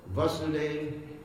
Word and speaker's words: Vasudeva: There's two words, Vasudeva and Vasudeva. You understand the difference in pronunciation? Vasudeva, Vasudeva. Vasudeva, with the long A, means the Supreme Personality of Godhead, the All-Pervading Lord, like Om Namo Bhagavate Vasudevaya.